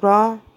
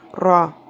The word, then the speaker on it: Ra: Please do take note that the Ra, some people pronounce as Ra. The correct way to do it is the rolling of the tongue, Ra.